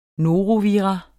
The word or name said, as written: norovirus